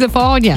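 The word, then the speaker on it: Le Fanu: It's spelled Lefanu. – Yeah. – But apparently it's Lefania.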